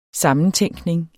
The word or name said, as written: sammentænkning